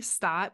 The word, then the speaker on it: Stott: So I'm Nikki Stott, but I spell it weird.